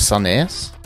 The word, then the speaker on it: Sandnes: Prøv å uttale det med trykk på siste delen av ordet. Sandnes. – Sandnes. – Sandnes? – Sandnes. Så det høres ut som en saus.